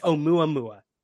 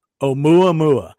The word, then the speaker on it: Oumuamua: Yeah, Oumuamua? My girlfriend's from Hawaii, so I actually got her take on this. It's Oumuamua. – Oumuamua. Well, all right.